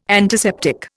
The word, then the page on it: antiseptic: Indian